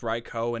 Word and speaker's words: Raikou: That combined with the fact that it's pronounced Raikou and not Raikou is so heartbreaking to me.